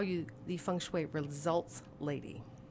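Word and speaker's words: Feng Shui (風水): And again, is it Feng Shui? Am I saying that correctly? – Depends on what country you're from as to how it's pronounced, and that's perfect.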